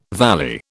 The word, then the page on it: valley: British English